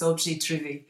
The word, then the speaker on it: objet trouvé: Yeah, the, the French terminology used by artists for a found object is objet trouvé, um, but okay.